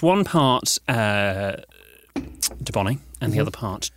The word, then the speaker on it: Dubonnet: And then we have a bottle of dubonnet. – Dubonnet. – Dubonnet.